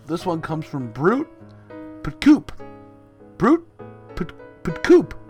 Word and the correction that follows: Putcup: I think it's Putcup. – Wow. I really flubbed that one. – Yeah, you butchered it.